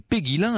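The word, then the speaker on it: Puyguilhem: Il est marquis de, alors ça s'écrit Puy-Guillem, mais ça se prononçait Péguilin à la cour, et il était comte, un jour il sera duc de Lauzun.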